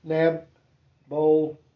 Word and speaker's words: Nabopolassar: B, the tribes were finally united under, and I'm going to do my best to pronounce this, and you may pronounce it, Nabopolassar. – That sounds right to me.